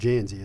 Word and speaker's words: Dzansi: You know, Janzy and by the way, it is pronounced Janzy. It's spelled D-Z-A-N-S-I.